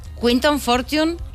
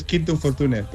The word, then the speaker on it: Quinton Fortune: Quinton Fortune, ¿lo hemos pronunciado bien? – Sí, sí. Bueno, aunque normalmente en España es Quinton Fortune, pero es igual.